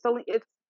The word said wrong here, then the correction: Zelina: I'm saying her name wrong. – Zelina. – God, wow, I'm so sorry. Zelina.